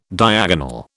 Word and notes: diagonal: British English

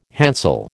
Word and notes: hansel: American English

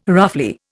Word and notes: roughly: Indian

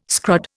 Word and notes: scrod: Indian

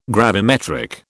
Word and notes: gravimetric: British English

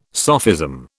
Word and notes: sophism: British English

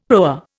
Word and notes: proa: Indian

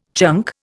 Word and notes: chunk: Indian

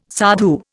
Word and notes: sadhu: Indian

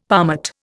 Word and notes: palmate: Indian

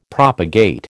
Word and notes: propagate: American English

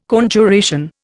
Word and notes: Conjuration: Indian

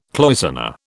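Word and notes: cloisonné: British English

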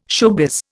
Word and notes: showbiz: Indian

